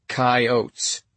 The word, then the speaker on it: coyotes: And when he did this, he pronounced coyotes pointedly with two syllables, coyotes.